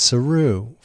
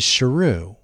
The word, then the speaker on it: Sheru: It was Sheru, which means lion.